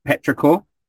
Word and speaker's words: Petrichor: And so I was also offered the services of a water genasi called Petrichor. – Yes, correct. – Who I keep mispronouncing their name as like Petrodan and Petrovic and stuff like that.